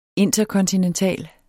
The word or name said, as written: interkontinental